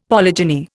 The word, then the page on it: polygyny: Indian